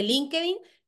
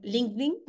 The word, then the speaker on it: LinkedIn: No es lo mismo para nada TikTok que LinkedIn, bueno, LinkedIn, que Igual. – aquí lo pronunciamos todo a lo canario, bueno, a lo español.